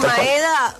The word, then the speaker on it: Maeda: Maeda, ¿cómo se pronunciará? ¿Maeda? – Así. No, decís sí, Maeda.